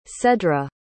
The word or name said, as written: cedra